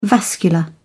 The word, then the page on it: vascular: British English